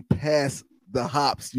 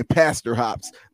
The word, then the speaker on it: Pass The Hop: Yo, but make sure when you pronounce his name, pass the hops, you make sure that you break it up because it sounds like you saying pastor hops.